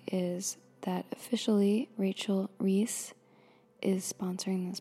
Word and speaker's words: Reis: Okay, I just checked an NPR snippet for the pronunciation of Rachel's name, and they say it as Reese, so that's how I will say it.